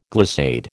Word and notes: glissade: American English

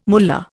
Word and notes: mullah: Indian

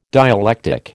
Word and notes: dialectic: American English

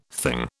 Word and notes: thing: British English

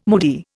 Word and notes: moody: Indian

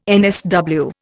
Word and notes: NSW: Indian

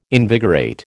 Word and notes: invigorate: American English

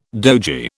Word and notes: dogie: British English

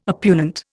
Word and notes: oppugnant: Indian

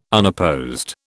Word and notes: unopposed: British English

